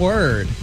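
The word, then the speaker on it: Wyrd: And before that, some Word. That's W-Y-R-D. Not W-E-R-D or W-O-R-D. It's W-Y-R-D.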